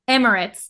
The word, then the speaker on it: Emirates: Emirates.